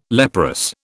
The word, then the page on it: leprous: British English